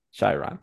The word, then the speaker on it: Chiron: How do you say that word? – Chiron.